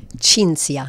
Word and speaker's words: Cinzia: Cinzia. – Cinzia. I wondered if it was a ch or a s. – Yeah, Cinzia. Italian. So the C-I yeah. is pronounced as ch.